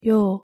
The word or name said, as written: ㅛ